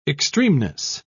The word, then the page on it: extremeness: British English